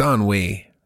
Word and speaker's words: ennui: Ennui? – It's ennui. But ennui? – You spell it. – It's E-N-N-U-I, right?